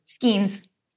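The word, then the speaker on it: Skenes: Is Skeens the pronunciation? – Skeens.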